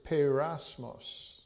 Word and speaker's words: peirasmos: The word is peirasmos. I'll spell it P-E-I-R-A-S-M-O-S, peirasmos.